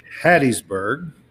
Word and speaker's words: Hattiesburg: This is the one that was played in Hattiesburg.